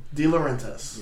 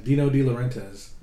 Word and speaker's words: De Laurentiis: De Laurentiis. – Yeah, Dino De Laurentiis.